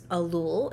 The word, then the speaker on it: Elul: In fact, we just entered into a new Hebrew month, which is Elul. It's spelled E-L-U-L, and it actually goes from, what are the dates?